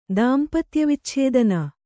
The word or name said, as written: ದಾಂಪತ್ಯವಿಚ್ಛೇದನ